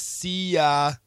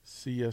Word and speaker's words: Seiya: I believe it's Seiya Suzuki. – Seiya Suzuki. – Seiya, S-E-I-Y-A Suzuki.